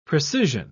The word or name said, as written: precision